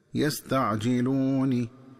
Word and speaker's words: يستعجلون: وكذلك عند وصل آخر هذه الآية بالآية التي تليها ينبغي التنبه للنطق الصحيح للفظ يستعجلون، فيقرأ بكسر النون هكذا يستعجلون.